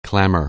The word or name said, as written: clamor